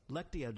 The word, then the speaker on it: Lectio: It's like the Latina Divina. Am I saying it right? – Lectio Divina, yeah.